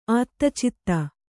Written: ಆತ್ತಚಿತ್ತ

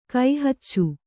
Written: ಕೈಹಚ್ಚು

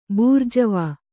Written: ಬೂರ್ಜ್ವಾ

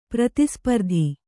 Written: ಪ್ರತಿಸ್ಪರ್ಧಿ

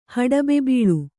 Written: ಹಡಬೆಬೀಳು

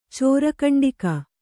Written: ಚೋರಕಂಡಿಕ

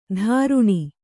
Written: ದ್ರುಣಿ

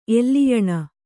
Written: ಎಲ್ಲಿಯಣ

